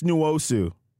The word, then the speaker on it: Nwosu: Also, quick side note, man, it's Nwosu. Uchenna Nwosu. Come on, people. Come on, Fox. I'm disappointed. Uchenna Nwosu. Come on, people. Read the pronunciation guide. My man is making too many plays for y'all to be messing his name up like that. Disrespectful.